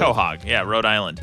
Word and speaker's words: Quahog: Quahog. Is that how you pronounce it? Quahog. – Quahog.